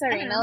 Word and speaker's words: Tsarina: I think it's Tsarina, like Ballerina.